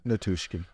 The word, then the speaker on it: Nichushkin: Nichushkin.